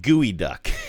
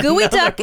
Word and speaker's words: geoduck: But gooey duck. – Yeah, gooey duck. One. Gooey duck really fucks with you. It looks like geoduck. – Uh huh. – And yet it is pronounced gooey duck.